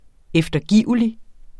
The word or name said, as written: eftergivelig